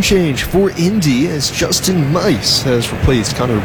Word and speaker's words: Meis: And it is Mice, M-E-I-S. Pronounced like the 3 blind mice.